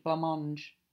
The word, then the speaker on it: blancmange: B L A N C M A N G E. Silent C. Blancmange.